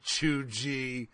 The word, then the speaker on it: cheugy: Fucking Cheugy. That's, you're so Cheugy that you pronounce it Cheugy. – But when I see G-E-E, it's like G golly, right? And they said Chew, C-H-E-W, G-E-E, G golly.